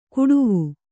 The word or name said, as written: ಕುಡುವು